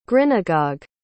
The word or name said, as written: grinagog